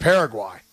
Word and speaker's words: Paraguay: So, I always pronounce it Paraguay. – Okay, I'll rock with Paraguay then because it doesn't rhyme with Turkey A and right.